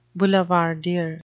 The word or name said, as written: boulevardier